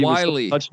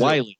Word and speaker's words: Whyle: I think it's Wiley, right? – Is Wiley.